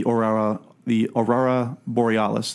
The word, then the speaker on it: Aurora Borealis: Just before they re-enter the Aurora Borealis.